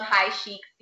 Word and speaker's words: Chic: but yes, it's um, High cheek high chic. Look at you, you got me saying it wrong. It's high chic society, and it's spelled high chic, C H I C.